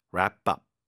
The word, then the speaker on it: wrap up: Wrap up, wrap up, 그렇죠? 자, W 발음은 거의 발음이 안 되고 R 발음 wrap up 이렇게 발음을 하는데 예문을 보시면 확실하게 이해가 되실 겁니다.